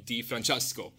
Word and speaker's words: Di Francesco: We have Johnny Di Francesco here today. And I had to put my Italian accent on to pronounce that.